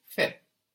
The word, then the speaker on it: fais: The S is silent, of course, and we get fait.